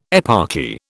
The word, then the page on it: eparchy: British English